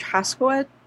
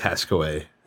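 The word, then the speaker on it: Hascoët: Um, the chief creative officer, I guess Serge Haskowitz, is how you pronounce that. – Mm-hmm. All these motherfucking French. – I, I, think I think it's Haskaway.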